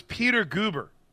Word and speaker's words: Peter Guber: Yeah, it was Peter Guber is how you say his name.